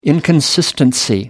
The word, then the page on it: inconsistency: British English